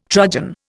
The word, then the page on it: trudgen: Indian